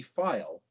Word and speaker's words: Pfeil: So coming up during that season was a young man named Bobby Feil, P-F-E-I-L. The P is silent, but his presence was not.